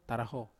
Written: taraho

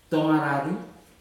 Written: toga rari

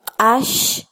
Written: qash

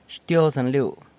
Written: Xtyozën liu

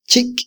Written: chiky

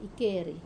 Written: keri

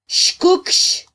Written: xkukx

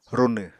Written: runw

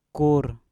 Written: kur

